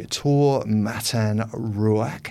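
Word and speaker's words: Taur Matan Ruak: We are hearing reports that the new Prime Minister will be Taha Mataruak. How's my pronunciation there, Sue? – Good.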